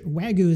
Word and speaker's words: Wagyu: And if you're not sure, Wagyu is, I hope I'm pronouncing it right, it's W-A-G-Y-U, and it's Japanese, right?